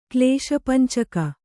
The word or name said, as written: ಕ್ಲೇಶಪಂಚಕ